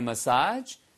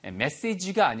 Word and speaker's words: massage: 그 다음에 after getting a massage. 지난번에도 말씀드렸죠? 발음이요. 메시지가 아니고요. massage. massage. 액센트가 뒤에 액센트입니다. 뒤에요.